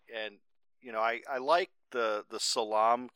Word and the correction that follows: Salem: character, if I'm pronouncing that correctly. Salem.